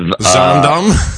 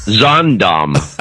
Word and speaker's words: Zaandam: Who is just giving us money for the sole purpose of hearing me butcher the town of Zaandam. – Zaandam.